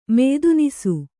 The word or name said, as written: ಮೆಯ್ದುನಿಸು